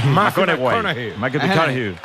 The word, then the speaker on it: McConaughey: ¿Mateo McConaughey? – Sí, sí, sí. Sí, sí. – Hay que ir corrigiendo la pronunciación. – McConaughey.